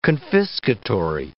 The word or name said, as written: confiscatory